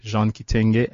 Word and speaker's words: Jean Kitenge: And our personal ones, mine is at Jean Kitenge, at J-E-A-N, as in Bogat Jean.